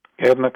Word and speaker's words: Gairdner: Did I blow the pronunciation of the foundation there? – Gairdner, correct? Yeah, Gairdner's correct.